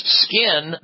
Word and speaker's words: 5K1N: And if you write that out, it actually looks like skin because the 5 looks like an S, and you have K, then the number 1, and then N. Pronounced Skin, their Sunless Tanner.